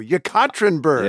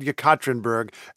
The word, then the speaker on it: Yekaterinburg: I looked it up for you. I knew this was going to be an issue. Yekaterinburg. – Yeah, I took a U-turn on that pronunciation.